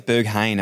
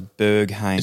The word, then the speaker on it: Berghain: There's this club in Germany called, I think it's Berheim. Is that how you pronounce it? – We're going to go. Berheim. I think it's Berghain, actually. – Berghain. – Yeah, I'm 99% sure it's Berghain.